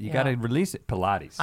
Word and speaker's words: Pilates: And I think it's pronounced Pilates. – Yeah, Pilates.